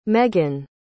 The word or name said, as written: meagan